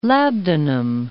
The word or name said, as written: labdanum